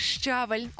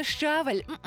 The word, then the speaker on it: щавель: У Стрію 15.30, ти слухаєш Стрій ФМ, як не опозоритися через щавель. Отак я обіцяла розповісти тобі, ну що ж, слухай і запам'ятовуй собі відразу, що правильно говорити, не щавель, а щавель і тільки так.